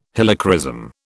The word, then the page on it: Helichrysum: British English